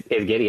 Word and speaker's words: Giddey: It's Giddey, right? That's how you say it? – It's Giddey, yeah.